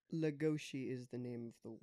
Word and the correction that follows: Legoshi: Legoshi. Legoshi. – Yeah. – Legoshi. – I just said it wrong. My bad. – Yeah, you gotta have that right accent on it.